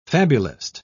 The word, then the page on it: fabulist: British English